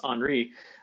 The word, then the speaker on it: Henri: But Henri, which I mispronounced earlier this week because it's spelled Henry, but it's Henri, that particular system is going to strengthen over the next couple of days here.